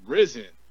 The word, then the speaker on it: Rizin: And they announced my name as I spelled Arsin, but they kept pronouncing Rizin.